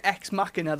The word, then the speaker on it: Ex Machina: It's definitely Ex Machina though, isn't it? – Yes. – It's based on the Latin Deus Ex Machina.